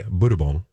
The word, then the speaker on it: Bourbon: So, and I'm sorry, the tuna mayo, like there are two flavors being introduced by the Japanese company Budubon, which is just bourbon, but that's how they say it.